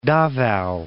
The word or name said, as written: Davao